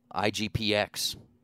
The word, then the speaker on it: IGPX: Right now we're looking at International Grand Prix, or IGPX, that's how you say it, versus Boruto, one of the multiple Naruto arcs.